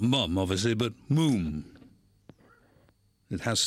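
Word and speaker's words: Múm: but it's got an accent over the U, so it's pronounced Moom, in the same way the pulp is pronounced Poolp.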